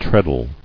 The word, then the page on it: Treadle: tread·le